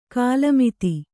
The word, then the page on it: ಖಲಮತ: khala mata